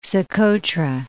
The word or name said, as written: Socotra